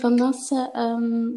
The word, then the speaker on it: Vanassche: So I'm Eline Vanassche.